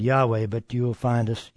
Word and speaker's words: Yahweh: And I have put up here, it's spelled YHWH, and generally pronounced Yahweh, but our translator forefathers chose to put points and vowels in there, and they called it Jehovah.